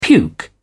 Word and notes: puke: British English

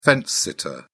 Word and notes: fence-sitter: British English

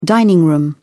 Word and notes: dining room: British English

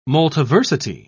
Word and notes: multiversity: British English

